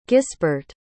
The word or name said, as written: gisbert